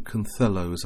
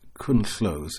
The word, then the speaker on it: Cynllo: It's spelt C-Y-N-L-L-O. And it's supposed to be pronounced St. Cunthelos. I suspect it's Cunthlos in Coedi Bryn.